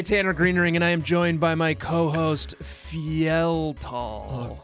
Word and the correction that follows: Yveltal: God, uh, Yveltal.